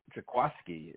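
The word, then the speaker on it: Jaquiski: I mean, the Eagles signed Jaquaski, is how you pronounce it, Jaquaski Tart from the 49ers, was with the 49ers for 7 seasons.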